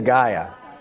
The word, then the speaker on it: Gaia: Is that how you pronounce it? G-A-I-A? The Revenge of Gaia.